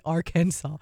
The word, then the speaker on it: Arkansas: Arkansas. Silent S whenever we say it's like likely or convenient. Let's just not mention the S.